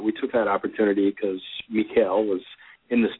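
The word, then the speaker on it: Michaël: Tim, it wasn't until earlier this afternoon that I was told that Michael Roskam, or Mikhail, excuse my mispronunciation there, was a painter.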